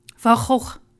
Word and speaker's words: Van Gogh: Van Gogh. Now try and repeat that. – There's no way I could do that. Gogh. – Say the whole name. – Vincent van Gogh.